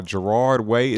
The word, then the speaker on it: Gerard Way: Today I picked up Doom Patrol by Gerard Way.